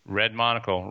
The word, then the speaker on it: Red Monocle: Red Monocle. Red like the color, monocle like an eyeglass.